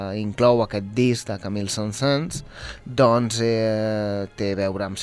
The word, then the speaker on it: Saint-Saëns: Molt bé, comencem amb, crec que és, Saint-Saëns? – Saint-Saëns,